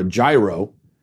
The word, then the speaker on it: gyro: And I noticed, for the first time, I noticed that the guy behind the counter referred to it as a gyro. I think that's how you pronounce it. Gyro, like with a Y. And I thought, is that? And then I looked it up and I went, that's actually what I'm supposed to be saying.